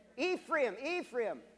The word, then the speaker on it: Ephraim: Ephraim was a, and did I say that in Spanish? However it is in English. Ephraim. How do you say it in English? Ephraim? How do you say it? It's Ephraim, sorry.